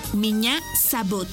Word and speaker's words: меня зовут: Y se escribe P-R-I-V-I-E-T. Привет. ¡Muy bien! Repite conmigo. Привет. ¡Muy bien! En ruso, me llamo se dice меня зовут. ¿Y tu nombre? Una vez más. Меня зовут. Por ejemplo, меня зовут. Esta palabra se escribe меня. M-I-Ñ-A. Меня. Зовут. Z-A-V-U-T.